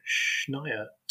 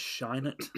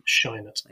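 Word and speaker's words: Scheinert: This is a sci-fi comedy drama written and directed by the duo known as Daniels, Daniel Kwan and Daniel Schneiert, if I probably not pronounced that name correctly. – I think it's Schneiert. – Schneiert, anyway.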